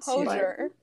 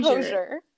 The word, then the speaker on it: Hozier: Hozier. Yeah, I don't know how the fuck he pronounces that boy's name. – It's Hozier. It's Hozier.